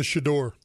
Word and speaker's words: chador: It's a chador.